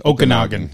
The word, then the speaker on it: Okanagan: So with the is it Okinawan? How do you pronounce their name? The Denver team that they Okanagan? – Played in. Okanagan. Yeah. Thank you.